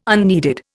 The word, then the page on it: unneeded: Indian